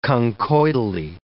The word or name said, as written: conchoidally